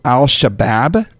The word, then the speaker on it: Al-Shabaab: Al-Shabaab, is that how you pronounce it? – You've got that correct.